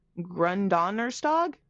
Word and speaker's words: Gründonnerstag: Quote, Gründonnerstag, which means Green Thursday.